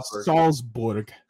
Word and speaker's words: Salzburg: How you say that? – Salzburg. That's how you pronounce it.